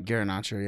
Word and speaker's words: Garnacho: But I'm interested to see if Garnacho. How do you say his name? – Garnacho, yeah.